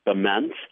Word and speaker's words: Bement: Following towns are pronounced Monticello, Bement, Hoopston, which is in the 217 area code, just like Springfield, but it's actually 5 miles from Indiana.